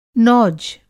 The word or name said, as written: नौज